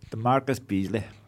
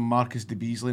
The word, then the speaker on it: DeMarcus Beasley: DeMarcus Beasley. – Right, okay. Because there's this commentator out there that was calling him Marcus de Beasley, and that was never his name.